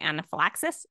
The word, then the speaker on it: anaphylaxis: Like the first time that he had went to anaphylaxis, that's how you say it, right? – Yes.